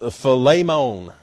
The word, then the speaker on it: Philemon: But in Greek, it says it's pronounced Philemon. And that sounds kind of like a steak to me, but I like Philemon.